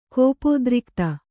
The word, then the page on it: ಕೋಪೋದ್ರಿಕ್ತ: kōpōdrikta